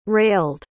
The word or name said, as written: railed